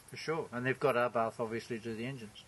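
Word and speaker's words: Abarth: Is it Arbath? – Arbath. It's pronounced Arbath. – It's Scorpio. – But it's A-B. A-B-A-R-T-H.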